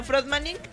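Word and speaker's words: Fröttmaning: Como suena, Frotmanin, pero con doble T.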